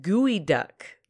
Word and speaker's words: geoduck: So it is actually a gooey duck. That's how it's pronounced, but it's spelled G-E-O-D-U-C-K, so it looks like geoduck.